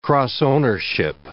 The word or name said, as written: cross-ownership